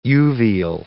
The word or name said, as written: uveal